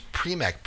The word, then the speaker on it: Prymek: Bandcamp, Chaz Prymek, P R Y M E K. I think I'm pronouncing that correctly. – Yeah, you're nailing it.